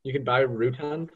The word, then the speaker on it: Routan: It's pronounced Rutan. – Rhymes with crouton.